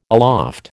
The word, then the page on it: aloft: American English